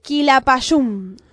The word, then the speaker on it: Quilapayún: Que acá el operador me dijo que se pronuncia de esta manera, a ver si me sale. Quilapayún.